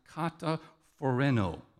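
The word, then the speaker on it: kataphoreno (καταφρονέω): And I'm going to get nerdy on you, but it's literally pronounced kataphoreno, okay? Kataphoreno. It's two words. Phoreno means, has to do with your mind, your thinking. Kata means down.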